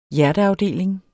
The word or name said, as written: hjerteafdeling